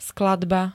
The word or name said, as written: skladba